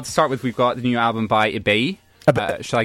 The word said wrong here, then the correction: Ibeyi: So that's Ibeyi. – I'm not familiar with three syllables.